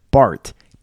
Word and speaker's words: baht: No, it's pronounced like baht. B-A-H-T. That's how you spell it.